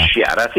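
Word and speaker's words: Ishihara: Ishiara, sì, sì.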